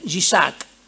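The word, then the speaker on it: Yishak (יצחק): Viene del hebreo, ¿ya? que se pronuncia Yishak.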